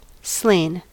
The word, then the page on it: slain: American English